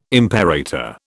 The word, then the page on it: imperator: British English